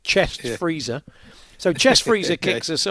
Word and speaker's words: Frieser: F-R-I-E-S-E-R, pronounced Freezer.